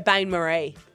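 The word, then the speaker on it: bain-marie: So apparently I've been saying this wrong the whole time. – Yeah, Bain Marie.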